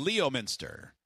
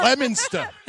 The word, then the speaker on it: Leominster: Leominster. – Oh, yeah, it's definitely spelled like that. L-E-O, Minster. I see it. – Leominster. – Stop pronouncing the R so hard.